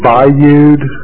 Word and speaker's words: Bayoud: It's David Bayoud, B-A-Y-O-U-D.